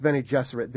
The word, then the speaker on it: Bene Gesserit: What religions mostly go into the B'nai Gesserit? – Well, and that I must have mispronounced. – I used Bene Gesserit because I was thinking of female Jesuits.